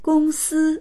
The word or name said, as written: gong1si1